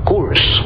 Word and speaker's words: Coors: Hay una cervecería en Estados Unidos que se llama Coors. Se escribe Coors, C-O-O-R.